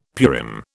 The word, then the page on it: Purim: British English